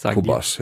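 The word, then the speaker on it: Qobuz: Auf Kubus und wie spricht man es eigentlich wirklich aus? – Kubas, ja.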